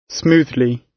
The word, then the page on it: smoothly: British English